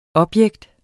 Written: objekt

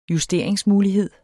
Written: justering

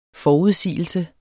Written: FORUDSIGELSERNES

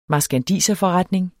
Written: marskandiserforretning

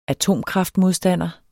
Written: atomkraftmodstander

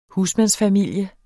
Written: husmand

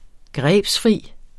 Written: grebsfri

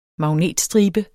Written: magnetstribe

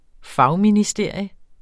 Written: fagministerium

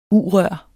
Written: U-rør

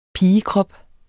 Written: pigekrop

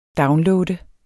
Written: downloade

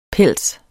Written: PELSENES

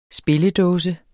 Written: spilledåse

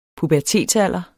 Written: pubertetsalder

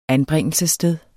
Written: anbringelsessted

